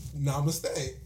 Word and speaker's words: namaste: And then at one point, I was like, namaste.